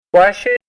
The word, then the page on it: washi: Ninilchik Russian